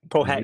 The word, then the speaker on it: Powhatan: Dang, I know how to say this word because it's a street name out there. Powhatan? – Powhatan.